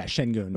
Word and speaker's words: Şengün: And you saw the kid, Şengün, how do you say his name? – Şengün.